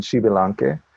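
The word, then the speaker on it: Xbalanque: The artwork itself is based off of or inspired by a Mesoamerican creation myth, which is there were hero twins, and I want to get the pronunciation right. It's Wunapu and Xibilanque, and they were twins.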